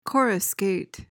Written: coruscate

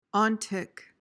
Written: ontic

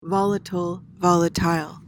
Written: volatile

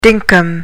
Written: dinkum

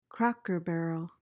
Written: cracker-barrel

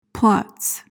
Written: plotz

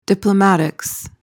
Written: diplomatics

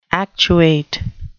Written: actuate

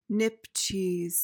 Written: nipcheese

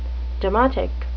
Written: demotic

